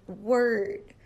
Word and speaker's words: word: Now, one thing to remember is that they both have the wr sound. So wr, and then world, we want to make sure that you pronounce the L. World, world, world. Now the word word, we have no L sound. It is word, word. So let's listen to the difference. World, word, world, word.